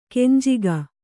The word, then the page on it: ಕೆಂಜಗ: kenjaga